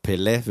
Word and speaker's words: Pelé: Genau, Pelé wird er ausgesprochen.